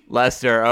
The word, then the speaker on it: Leicester: Leicester.